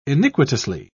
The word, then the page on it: iniquitously: British English